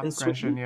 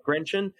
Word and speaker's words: Grenchen: Because the actual velodrome itself, I think, is it Grenchen? Is that how you pronounce it? – Yeah, Grenchen, yeah. – Yeah, Grenchen.